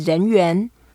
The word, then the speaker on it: 人缘: The way to say popular or popularity is 人缘。